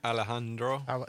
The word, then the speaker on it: Alejandro: How would you pronounce that? – Alejandro. – You don't pronounce the J.